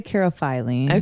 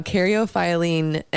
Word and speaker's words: caryophyllene: I say caryophyllene. – Okay. So everyone says it different. – I know. Caryophyllene is the way I got on YouTube and went to the pronunciation, because I was like, what is this?